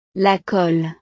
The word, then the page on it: la colle: frances